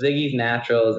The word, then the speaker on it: Ziggy's Naturals: Is that how you pronounce it? – Yeah. So it's Ziggy's Naturals, and Ziggy is actually my wife's name.